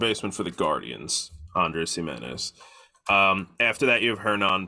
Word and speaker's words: Giménez: You have Andrés Giménez. That's how you say it, right? – I think it's Giménez.